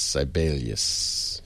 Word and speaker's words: Sibelius: Chat saying it's pronounced Sibelius, not Sibelius. Sibelius. Ah, thank you.